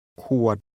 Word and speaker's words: ขวด: ขวดขวด This word is pronounced with a low tone.